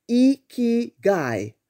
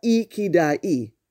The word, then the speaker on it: Ikigai: The correct pronunciation is Ikigai, not Ikidai.